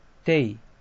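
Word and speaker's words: 丁: 音読みで読みますと、こう、おつ、へい、てい、ぼ、き、こう、しん、じん、き、と読みます。これだけで読むと少し分かりにくいんですが、この文字はですね、 2 つに分かれていまして、まあ、もうそうですね、読んでみましょうか。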